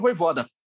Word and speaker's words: Vojvoda: Mas o Flamengo vai pegar o Fortaleza, que está muito bem treinado pelo Vojvoda.